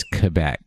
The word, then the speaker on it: Quebec: No, it's Quebec.